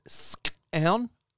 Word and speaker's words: skxawng: So, like, the word scown? But remember, scown means moron, but it's scown. It's like, because I write it down, it's pronounced, it's spelled S-K-X. – Yes. – S-K-X-A-W-N-G. But the K-X is a a click sound.